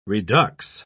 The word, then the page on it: redux: British English